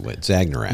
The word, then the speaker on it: Zagnarak: The Zagnaraks. – I cannot pronounce it. Zagnarak. – Zagnarak. Think of Ragnarok, but like with Zagnarak.